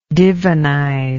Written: divinize

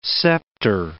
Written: scepter

